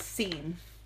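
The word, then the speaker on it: Cygne: It's C-Y-G-N-E. – Yeah, but it's pronounced scene, which is very interesting.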